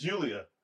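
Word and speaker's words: Giulia: She's a Japanese broad, and her name is Julia, and it starts with a G.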